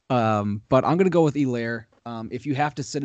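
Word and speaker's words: Helaire: My sit-em is Edwards, Elaire. It's Elaire. I think the H is silent.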